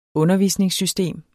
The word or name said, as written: undervisningssystem